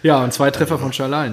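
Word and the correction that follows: Szalai: ne? Äh, ja, genau, Salai. Oder Salai, ja, sorry. – Ja. Falsch ausgesprochen. Genau.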